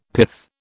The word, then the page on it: pith: British English